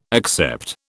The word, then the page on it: accept: British English